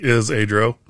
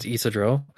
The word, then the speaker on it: Isidro: And before we go any further, I'm not going to forget it like we did with Baruch, but Portia's brother's name is Isidro. – Like is Adro. – Yep. That's how I've been pronouncing it to myself is like is Adro. So it's Isidro.